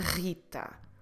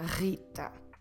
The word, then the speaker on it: Rita: In English, of course, Rita, but or Rita, but in Portuguese, Rita, with a rolled R here in your throat. Rita.